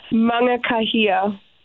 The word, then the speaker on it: Mangakahia: Mangakahia.